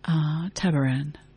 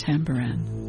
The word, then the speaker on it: Tabaran: Tabaran, sometimes pronounced Tambaran.